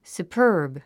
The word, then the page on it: superb: supə́ː r b; スウパーブ